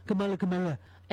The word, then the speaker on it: Kamala: And by the way, stop intentionally mispronouncing her name like you're friggin' 8 years old. Kamala.